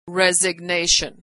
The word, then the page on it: resignation: American English